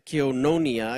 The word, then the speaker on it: Koinonia: The word fellowship, if you go deeper, actually comes from the Greek word Kiononia, Koinonia, excuse my pronunciation for all you Greek scholars, which means holding something in common. Holding something in common. The word Kiononia is used 20 times in the New Testament.